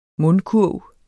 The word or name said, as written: mundkurv